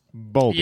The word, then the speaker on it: Bowlby: I've never said the word Bowlby out loud. Is that how you pronounce it? Bowlby. – Yeah, there's no way she got through life without getting made fun of for that last name.